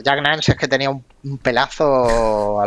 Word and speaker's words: Jack Nance: No lo he dicho mal, ¿no? Jack Nance. Jack Nance, vale, vale.